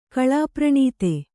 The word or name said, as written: ಕಳಾಪ್ರಣೀತ